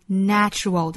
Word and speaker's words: natural: 要注意 nature 和形容词 natural 的发音区别。Nature, natural, natural, natural. 都同样是字母 A, 但是名词的大自然读成 nay, nature. 而形容词自然的读成 natural.